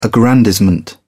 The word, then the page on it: aggrandizement: British English